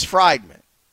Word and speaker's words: Friedman: Every time I look at that name, and I've known Josh for a long time, every time I look at his name, I'm like, I'm about to say it wrong. But it is Friedman.